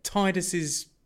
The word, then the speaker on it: Tidus: The want to get Tidus's ultimate weapon. – Apparently his name is pronounced Tidus.